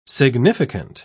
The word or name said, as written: significant